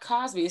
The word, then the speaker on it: Cosby: Her last name being Cosby, I don't know, but Crosby. – Oh, it looked like Cosby. It's spelled C-O-S.